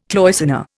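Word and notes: cloisonné: Indian

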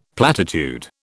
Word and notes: platitude: British English

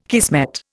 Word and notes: kismet: Indian

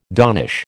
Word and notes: donnish: American English

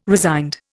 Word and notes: resigned: Indian

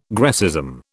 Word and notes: Grecism: British English

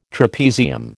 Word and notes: trapezium: American English

